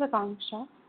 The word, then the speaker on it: Akanksha: Hi, this is Akanksha.